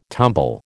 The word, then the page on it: tumble: American English